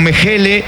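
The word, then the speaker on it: Mechele: Me corregirá si tiene tilde por ahí, pero es Brandon Mejele, camiseta 4. – Está muy bien la pronunciación. Brandon Mejele,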